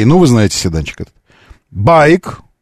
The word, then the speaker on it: BAIC: Э, байк. Ударение на первый слог. То, что пишется как B-A-I-C, байк.